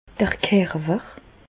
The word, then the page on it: d'r Kerwer: Bas Rhin; Reichshoffen